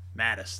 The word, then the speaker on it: Mattis: And, you know, our guy, uh, Mathis, and I might be pronouncing his name wrong, you know, and so if I am, you know, forgive me. Mattis, there we go.